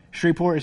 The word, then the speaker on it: Shreveport: Shreveport is how you say it.